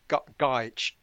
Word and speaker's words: Gaich: Gaich.